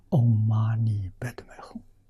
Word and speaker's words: Om Mani Padme Hum: Om Mani Padme Hum，啊，这是西藏读音。